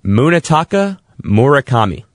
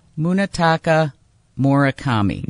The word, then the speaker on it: Munetaka Murakami: Munetaka Murakami. – Munetaka Murakami.